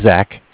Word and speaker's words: Zach: Okay, so my name's Zach, spelled Z-A-C-H, with Bank of Cardiff. Cardiff is spelled C-A-R-D-I-F-F, like Frank.